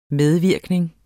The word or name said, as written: medvirkning